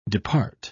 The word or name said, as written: depart